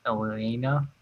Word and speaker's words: Ilyena: It's spelled with two L's, but they keep saying it like there's two syllables there. Ilyena.